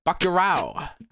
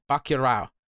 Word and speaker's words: Bacurau: Bacurau, I believe is how you say it. Bacurau. Mm-hmm. I can't roll my R's. I think there's a little bit of a rolled R in there, but I'm not really good at it.